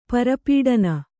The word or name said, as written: ಪರಿಪಠನ